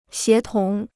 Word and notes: 协同: xié tóng

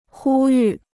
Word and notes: 呼吁: hū yù

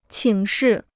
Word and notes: 请示: qǐng shì

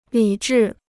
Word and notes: 理智: lǐ zhì

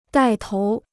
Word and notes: 带头: dài tóu

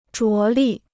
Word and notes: 着力: zhuó lì

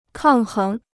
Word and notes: 抗衡: kàng héng

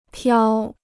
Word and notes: 漂: piào

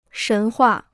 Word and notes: 神话: shén huà

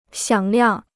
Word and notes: 响亮: xiǎng liàng

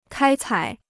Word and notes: 开采: kāi cǎi

